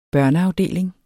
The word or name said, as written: børneafdeling